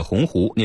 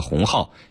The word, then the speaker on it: 鸿鹄: 在演讲中，林建华将立鸿鹄志中的鸿鹄念成了鸿号，引发广泛关注。